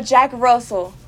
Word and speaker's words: Jack Russell: Is it like Jake? I would say yeah. Jake Russell Terrier. – A Jack Russell.